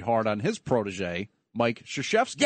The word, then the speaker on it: Krzyzewski: Mike Krzyzewski. – Oh, my God. You actually pronounced his name correctly.